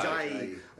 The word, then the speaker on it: Ajayi: How, how do you say his name? Ajayi. – Ajayi. Ajayi.